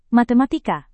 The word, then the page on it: matematika: Indonesian